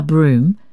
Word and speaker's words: brougham: spelt B-R-O-U-G-H-A-M, and an omnibus. One of the advocates pronounced the word brougham as a dissyllable, evidently unaware that the name of the vehicle, like the surname of Lord Broom, is abbreviated into a monosyllable.